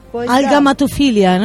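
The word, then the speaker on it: agalmatophilia: So let's go on with the other one, that is Algamatophilia. I'm sure I didn't say it properly. – Algamatophilia, no, that's perfect.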